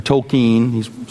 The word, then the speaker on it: Tolkien: The movies based on the book trilogy by J.R.R. Tolkien, or Tolkien, sometimes his name is pronounced.